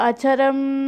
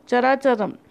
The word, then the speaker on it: Characharam: Ichit jagat, it jagat, techa, chara acharam. In Sanskrit, it is written like this. Tulsiya Rakshita Sarvam Jagat Techa Characharam. Not Acharam, Characharam.